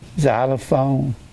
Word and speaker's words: xylophone: K-S-E-E. That's an X. Say, well, it don't sound like, I don't think an X sounds like a Z. You don't. Have you ever seen xylophone?